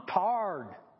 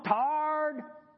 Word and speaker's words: tired: In Indiana, if you're not from Indiana, when we're really tired, like really, really tired, we don't just say that, hey, I'm tired. We say, I'm tarred. I'm tarred. I'm just tarred. You know how you spell that? T-A-R-D.